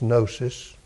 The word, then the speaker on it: gnosis: Gnosis, it's spelled with a G, but it's gnosis.